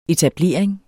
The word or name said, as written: etablering